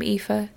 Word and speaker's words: Aoife: Aoife. It's Irish, spelled A-O-I-F-E, and though the book describes it as a mix of Ava and Eva and Eve, the character also says that her Irish mother is really the only person who can pronounce it correctly.